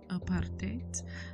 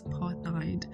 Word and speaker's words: apartheid: And yes, it is called, or it is pronounced as apartheid, but many people pronounce it as apartheid. But its pronunciation comes from the Afrikaans translation, which means apartheid.